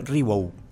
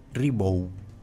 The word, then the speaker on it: Ribot: en su web específicamente se aclara que así como se escribe Ribot, se pronuncia Ribot, Ribot.